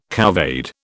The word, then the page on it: couvade: British English